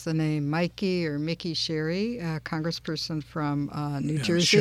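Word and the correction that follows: Sherrill: Cheryl, I think it is.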